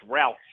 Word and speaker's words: Rausch: Bob Rush or Rush? – No, it's Rausch.